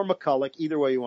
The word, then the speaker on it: MacCulloch: He said, you've been mispronouncing our name the whole year. I said, are you serious? What is it? He says, it's MacCulloch.